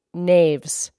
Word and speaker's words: knaves: No spells K-N-O-W, starts with a K. I know. It starts with a K. – Okay. Okay. So the word we're talking about right now is knaves. And it's spelled K-N-A-V-E-S.